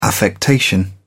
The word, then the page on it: affectation: British English